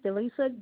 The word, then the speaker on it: Delisa: First name is Delisa, D-E-L-I-S-A, last name is Jones, J-O-N-E-S.